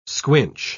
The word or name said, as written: squinch